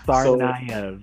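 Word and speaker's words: Tsarnaev: No, Sarnaev, so, the T is silent.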